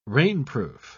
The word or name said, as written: rainproof